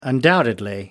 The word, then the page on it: undoubtedly: British English